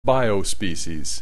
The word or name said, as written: biospecies